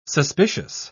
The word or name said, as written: suspicious